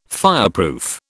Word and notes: fireproof: British English

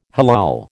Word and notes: halal: American English

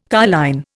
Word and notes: carline: Indian

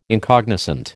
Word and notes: incognizant: American English

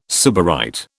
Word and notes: sybarite: British English

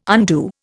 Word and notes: undo: Indian

